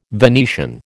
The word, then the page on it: Venetian: American English